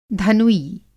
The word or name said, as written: धनुई